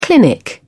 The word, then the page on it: clinic: British English